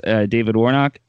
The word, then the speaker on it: David Warnock: Hey everybody, we have with us David Warnock.